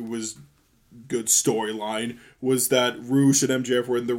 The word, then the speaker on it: Rush: It's spelled Rush, but it's pronounced Roosh.